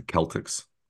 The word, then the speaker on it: Celtics: Is it A, the Celtics, B, the Clippers? – I believe that's pronounced the Celtics.